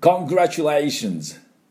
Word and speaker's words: Congratulations: コングラチュって強く言わなくていいです。チュって出すだけでいいですね。はい、では私の後で発音いきましょう。 Congratulations.